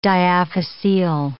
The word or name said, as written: diaphyseal